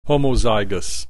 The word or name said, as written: homozygous